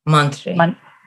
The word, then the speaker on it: Mantri: Mantri.